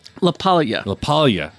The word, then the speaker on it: LaPaglia: Anthony LaPaglia, is that how you say it? – LaPaglia.